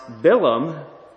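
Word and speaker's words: Bilam (בלעם): And another interesting thing, Balaam's name in Hebrew is pronounced Bilam or Balaam, which means devourer or destroyer of people.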